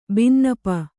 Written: ಬಿನ್ನಪ

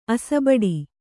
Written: ಅಸಬಡಿ

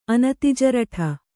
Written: ಅನತಿಜರಠ

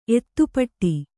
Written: ಎದೆಪಟ್ಟಿ

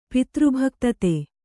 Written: ಪಿತೃಭಕ್ತತೆ

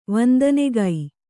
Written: ವಂದನೆಗೈ

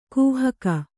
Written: ಕಾಹಿಗ

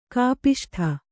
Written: ಕೋಪಿಷ್ಠ

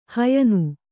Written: ಹಯ್ನ

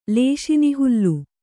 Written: ಲೇಶಿನಿಹುಲ್ಲು

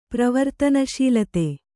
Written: ಪ್ರವರ್ತನಶೀಲತೆ

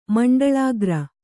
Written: ಮಂಡಲಾಗ್ರ

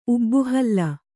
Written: ಊಬುಹುಲ್ಲು

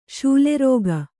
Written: ಶೂಲೆರೋಗ

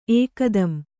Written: ಏಕ್‍ದಂ

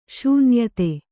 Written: ಶೂನ್ಯತೆ

